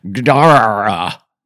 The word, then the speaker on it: Gdara: Something like, Gdara, with the accent on the second syllable.